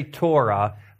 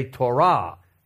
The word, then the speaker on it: Torah: Now in English we say Torah, but in Hebrew you tend to accent words on the last syllable. And so in Hebrew we say Torah.